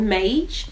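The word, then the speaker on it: Maje: I love M&S Marbury, M&S and I, I, always pronounce the name wrong, but i have only just found them. They're called Mage.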